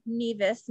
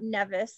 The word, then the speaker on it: Nevis: And I just realized that I mispronounced that earlier. Nevis, not Nevis, like a true novice would.